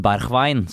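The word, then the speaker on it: Berghuis: Det er uh, kraftige og heftige rykter nå om, som vi har googlet oss fram til, Steven Berchwein, som han uttales vel på nederlandsk.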